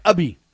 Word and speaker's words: Ubhi: Now, I don't know how to articulate this guy's name, but his name is Deep Uppy. U-B-H-I. How do you say that last name? Deep Uppy, or Ubby.